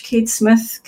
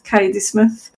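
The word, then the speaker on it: Kaede Smith: we both pronounce it different ways as well. It's K-A-E-D-E-S-M-I-T-H, Cade Smith, Kaiade Smith, on Twitter under the same name.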